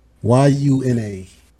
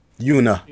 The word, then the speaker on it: Yuna: Y U N A. Oh, Yuna. Yuna.